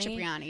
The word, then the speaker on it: Cipriani: He took me to, how do you pronounce it, Cipriani? – Cipriani, yeah.